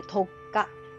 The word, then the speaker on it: 特価: これ特価。漢字で特別な価格と書いて特価と読みます。小さい「つ」が入ってます。